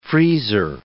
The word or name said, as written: freezer